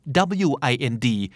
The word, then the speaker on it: wind: wind สะกดเหมือน wind ที่แปลว่าลมนะฮะ wind แต่ถ้าเป็น verb อ่านว่า wind wind